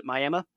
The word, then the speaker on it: Miami: a little town called Miami, or locals pronounce it Miami.